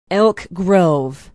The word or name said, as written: elk grove